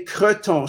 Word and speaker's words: cretons: Okay, there are two ways of saying it. That some say garton, and that's G-O-R-T-O-N-S, garton. – Yes. – Some say creton, C-R-E-T-O-N-S, and they're both acceptable.